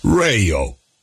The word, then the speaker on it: Rayo: Reijo. Ihan täynnä radioita ja podcasteja. Lataa appi kaupasta ja saat ilmaisen kokeilujakson, jonka jälkeen maksat siitä, no, et mitään. Se on ilmainen. R-A-Y-O. Reijo.